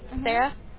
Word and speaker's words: Sara: S-A-R-A, Sarah.